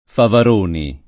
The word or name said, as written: Favaroni